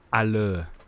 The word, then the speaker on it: Haller: So I've heard it's officially Haller. – Yeah, he is French.